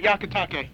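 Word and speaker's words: Hyakutake: Now, how exactly do you pronounce comet Yakutake? – Just like that, I think. – Don't tell me I'm getting it right, because I've been mispronouncing it all night until now. – You're pronouncing it right now, as far as I can tell.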